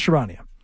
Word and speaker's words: Charania: Shams, how do you pronounce Shams' last name? Sharania? Yeah, Shams Sharania.